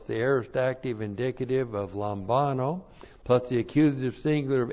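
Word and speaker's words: Lambano (λαμβάνω): And then we have lambano, the verb lambano, which looks like this in the Greek. L-A-M-B-A-N, long O, Lambano.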